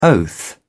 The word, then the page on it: oath: British English